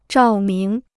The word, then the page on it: 照明: zhào míng